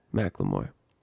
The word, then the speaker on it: McLemore: McLemore.